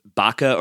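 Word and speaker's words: Bucha: We saw these horrific photos out of, I think it's pronounced Bacha, or yeah. B-A-C-H-A. It's a suburb of Kiev.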